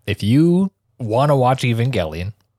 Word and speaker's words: Evangelion: The end of Evangelion is like, like Evangelion, I guess, to say it correctly.